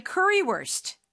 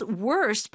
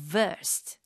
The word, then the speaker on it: Currywurst: And for those of you who may not know German, but the W there is pronounced as a V. – Yeah. – So it's spelled worst, but it's wurst.